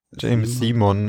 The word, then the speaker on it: James Simon: Rames Simon. Das habt ihr schön gemacht. Galerie. Ja. James. – James Simon.